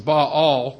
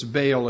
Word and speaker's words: Baal: Should be pronounced Baal. We pronounce it as Baal. It's just simply easier.